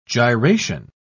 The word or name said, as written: gyration